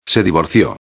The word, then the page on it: se divorcio: espanhol